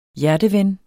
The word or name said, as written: hjerteven